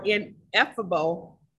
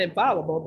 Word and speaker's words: ineffable: Uh, the meaning of the idiom X factor is an un unidentifiable or ineffable, uh, N E I N E F F A B L E ineffable. That pronounced that way. In, not infallible, but maybe ineffable quality that makes someone very special.